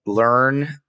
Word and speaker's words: Lurn: you leveraged your hard skills that you learned, finance and sales from your car sales days, into your first foray into affiliate marketing with Onik. Can you give us an overview of what Learn, it's spelled L-U-R-N, right?